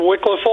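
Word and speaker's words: Wickliffe: Am I saying that right? – That's correct. Wycliffe, Ohio.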